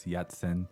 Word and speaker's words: Yatsen: 那其实逸仙英文里面那个发音是广东话的发音。 – Okay, so 逸仙 becomes Yatsen.